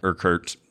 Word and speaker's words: Urquhart: And we went to Urquhart Castle, though a lot of the natives were saying something more like Urquhart, something like that. But it's spelled U-R-Q-U-H-A-R-T. – Oh, whoa. – So it looks like Urquhart.